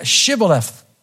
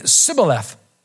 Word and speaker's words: Shibboleth: Then they would say, "Then say Shibboleth." And he would say Sibboleth, for he could not pronounce it right.